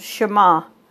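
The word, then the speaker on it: Shema (שמע): The word listen in Hebrew is pronounced Shema, a common word in the Bible, but the meaning of the word goes far beyond what our ears hear.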